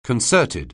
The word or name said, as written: concerted